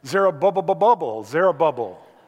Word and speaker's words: Zerubbabel: Zerubbabel.